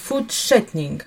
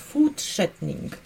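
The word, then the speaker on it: fortsättning: Обратите внимание, как э, пишется это слово. Пишется форт, форт, и потом еще идет буква «С». И вот это сочетание «РТС», оно не слышится как «РТС», обратите внимание, «food shortening», совсем по-другому.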